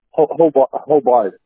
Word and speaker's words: Hobart: Hobart, H-O-B. It's H-O-B-A-R-T. Everybody says Hobart. You spell it, you pronounce it the way it sounds, or the way it's spelled, it's Hobart.